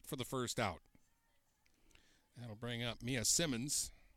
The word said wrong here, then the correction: Simons: or Simons, rather. I always say it wrong the first time, and I don't know why.